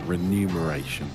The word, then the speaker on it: remuneration: The remuneration, I think is how you say it, because I used to always say it wrong. Remuneration. – Don't focus on the money, though. – It's the one you don't think it is. It's remuneration.